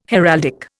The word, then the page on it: heraldic: Indian